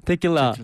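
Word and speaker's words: Tequila: 지소울 씨의 테킬라 맞나요 발음? 네맞아요 네. 테킬라 네.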